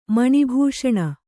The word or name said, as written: ಮಣಿಭೂಷಣ